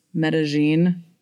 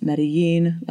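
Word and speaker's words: Medellín: As far as my update, I'm here in Medellin for 2 more months, and actually, the local dialect pronounces Medellin with that J sound, but the rest of the country calls it Medellin like we would normally pronounce it in Spanish.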